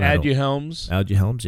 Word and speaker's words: Aduhelm: Though it should be noted that Biogen, the manufacturer of this drug called Aduhelm's, I might be butchering that. – Aduhelm's. – Yeah, there you go.